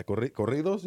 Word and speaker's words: corridos: A what? Yeah, corridos, you mean? – She count? Corico? Did I say it wrong? – No, you said it wrong.